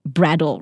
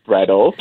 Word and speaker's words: BRDL: So I had the joy of being able to check out the bar restaurant concept called Brattle, right? – That's right. You're pronouncing it right too. It's spelled B-R-D-L, pronounced Brattle, just because we are where we are and we want to tell everyone that, look, we're in your neighborhood and your vicinity.